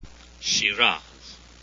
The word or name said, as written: shiraz